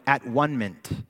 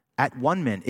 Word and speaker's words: atonement: Probably would have been uh, pronounced something like at one mint. At one mint.